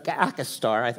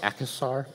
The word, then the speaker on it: Akhisar: Very little of the ancient city of Thyatira still exists, but if you were to travel to the modern Turkish city of Akhisar, I think I'm saying that right, Akhisar, it's A-K-H-I-S-A-R, look it up on Google Maps, you will find the ancient ruins of Thyatira right smack dab in the middle of town.